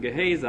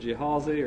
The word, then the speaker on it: Gehazi: I pronounce it Gehazi, and if you want to pronounce it Jehazi or anything else, well, you feel free to do so, because I'm not very good on pronunciation anyhow.